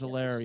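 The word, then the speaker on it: Helaire: Allaire, is that how you say his name? – Clyde Edwards Allaire, yeah.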